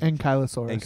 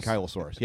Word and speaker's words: ankylosaurus: How do you pronounce it? – Ankylosaurus. – Ankylosaurus, yeah. Anyway, they named it after him.